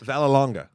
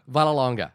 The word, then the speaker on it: Vallelonga: The interesting thing about this film as well is it's written by Tony Lip's son, uh, Nick Villanova. Uh, Villanogo. Is that how do you say it? I, I should know this. Vallelonga. Vallelonga.